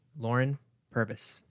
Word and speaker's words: Lauren Purvis: Uh, so here we have the one and only Lauren Purvis.